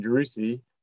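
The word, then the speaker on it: Driussi: And then your current teammate, Drusi, I don't pronounce his name, Sebastian Drusi, was for Argentina. Am I saying that really badly? – You actually said it perfect.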